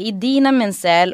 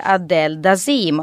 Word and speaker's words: Idina Menzel: Hon heter Idina Menzel och han ska presentera henne och säger Adele Dazim.